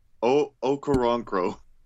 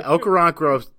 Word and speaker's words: Okoronkwo: I'm just going to say his last name, Okoronkwo. I think that's right. Okoronkwo is how I've been pronouncing it. You know, I think you nailed it there.